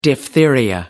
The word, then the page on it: diphtheria: American English